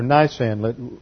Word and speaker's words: Nisan: Now, in Jewish understanding, on the 14th to 15th, it is on the 15th of Nisan, or Nisan, really, I think is the correct pronunciation, N-I-S-A-N, which is roughly comparable to our March-April.